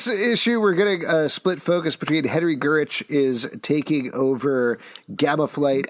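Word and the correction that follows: Gyrich: I think it's Gyrick.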